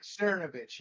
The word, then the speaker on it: Cernovich: I believe I found it through, is his name pronounced Cernovich? He's a right wing, I think, like journalist type person. – Mike Cernovich. Yeah, he actually is a love hate guy.